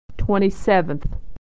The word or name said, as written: 27th